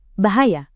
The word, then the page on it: bahaya: indonesian